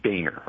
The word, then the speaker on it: Boehner: His name is Boehner, okay? John Boehner. We don't have to like him, but we'll at least try to pronounce his name right.